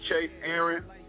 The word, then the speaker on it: Chase Aaron: Am I saying your name right? – Oh, Chase. Chase Aaron. – Yeah. There it is. – Yeah. C-H-A-S-E-A-A-R-O-N .com.